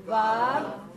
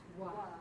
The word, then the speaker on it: Vav: I've heard Vav pronounced Wa. – Mm-hmm. In this class, we will pronounce it as Va. Okay? Vav, not Wa.